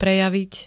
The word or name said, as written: prejaviť